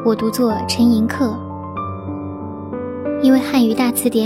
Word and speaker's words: 陈寅恪: 而且现在很多电视节目中也是这样叫法，所以我在这里也读作陈寅恪。